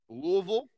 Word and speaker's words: Louisville: Louisville, that's how you say it.